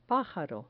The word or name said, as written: pajaro